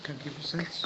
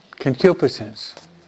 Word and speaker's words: Concupiscence: Concupiscence. – Good. Concupiscence.